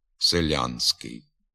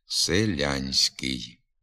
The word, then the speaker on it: селянський: Крім того, у надністрянських говорах не пом'якшується і звук «с» у суфіксі «ськ». А отже, послідовно твердо вимовляється і звук «н» перед твердим «с». Селянський. Треба пом'якшувати обидва звуки. Селянський.